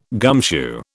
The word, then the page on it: gumshoe: British English